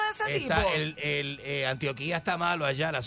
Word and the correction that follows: Antioquia: No se dice así. ¿Y cómo se dice? Antio- Antoquia. – Antioquia, eso mismo, Antioquia.